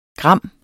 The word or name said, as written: gram